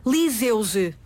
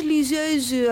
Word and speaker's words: liseuse: E então, quer seja aparelho para ler ou o casaquinho, portanto as palavras significam várias, podem significar várias coisas, em português nós pronunciamos liseuse. – Pronto. – Em português. – Para que não haja dúvidas. – Em francês, liseuse, alors, si je peux, si je veux parler français.